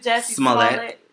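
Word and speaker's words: Jussie Smollett: How do you say it? Is it Jesse Smollett? – Right.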